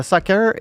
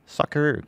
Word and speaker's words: soccer: Non, mais c'est parce que on dirait que le soccer est. Soccer, comment, comment j'ai prononcé ça, ce mot-là? C'est épouvantable. Soccer.